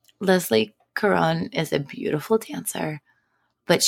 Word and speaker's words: Leslie Caron: And are you proud of me for pronouncing Leslie Caron's name correctly? – Instead of making it super fancy like you always do. Leslie Caron.